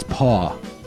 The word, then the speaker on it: Paw: Not pa, it's paw. P-A-W. – Yeah, you have to say it like you're talking about an animal hand.